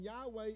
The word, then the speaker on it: Yahweh: And Yahweh is the pronunciation of those four letters.